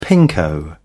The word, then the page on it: pinko: British English